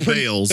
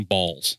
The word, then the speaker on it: Baals: They pronounce it Bales. – The pronunciation is B, or not the pronunciation. The spelling is B-A-A-L-S. Okay. The family says Bales, but apparently when this guy was in office, he wanted people to call him Balls.